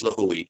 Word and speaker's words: Lihue: Lihue? Is that how you say it? – The main town, you're correct, for Kauai is Lihue.